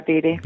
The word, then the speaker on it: Beattie: Yeah, Beattie.